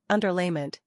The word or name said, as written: Underlayment